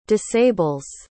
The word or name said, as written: disables